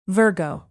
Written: virgo

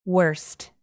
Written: wurst

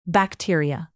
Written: bacteria

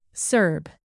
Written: serb

